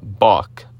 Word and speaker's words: Bach: Barbara Bach, like Bach the famous musician.